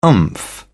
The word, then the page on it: oomph: British English